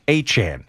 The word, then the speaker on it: Etienne: Yeah, Travis now Achan is the way he pronounces it, like Devon Achan of the Dolphins.